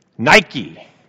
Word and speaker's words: Nike: Now, that Greek word is Nike. You know how you spell it in the English? N I K E. Nike. That's the image of success.